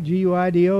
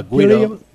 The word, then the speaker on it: Guido: And I'm pronouncing that right? G U I D O. – Uh, Guido. Yeah, Guido.